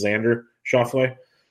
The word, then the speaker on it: Xander Schauffele: And that's how you pronounce his name, if anybody's curious. It's Xander Schauffele.